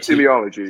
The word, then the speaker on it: teleology: and perhaps Kant recognized, is that the goodness is tied in with this teleology, and is that even how you pronounce it? I think it is, right? – Yeah, teleology, yeah.